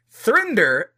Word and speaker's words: 3nder: How do you pronounce it? Thrender launched to help people find more than one partner at once. So, it's the number 3, N-D-E-R, for those of you already interested.